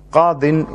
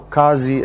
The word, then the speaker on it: Qadi: Qadin in Arabic, or Qazi as some pronounce.